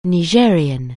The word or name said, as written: Nigerien